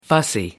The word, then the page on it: fussy: American English